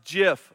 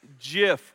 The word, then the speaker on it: GIF: But the inventor of the word says that, or the acronym says that it is indeed pronounced GIF, like the peanut butter. It's solved now. It's GIF with like a J sound, all right?